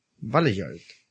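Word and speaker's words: Valeyard: Pero en este, yo no me acuerdo, mencionan también al famoso al Veiljar, no sé cómo se pronuncia. – Veiljar. – Vale, de pequeña, A-L-E, Yard. Vale Yard.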